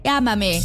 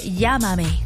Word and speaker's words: Llámame: Ay, sí es cierto, dice Ámame. No llega a pronunciar la doble L. Pero dice Llámame. Es cierto.